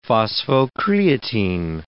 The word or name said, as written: phosphocreatine